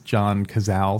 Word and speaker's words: John Cazale: I think once he's out in Vegas, I think that's John Cazale. Is that how you say his name? – Yeah.